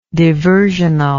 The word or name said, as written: diversional